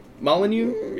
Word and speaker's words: Molyneux: Am I saying that right? Molyneux? – Yeah. – I think so. – Molyneux.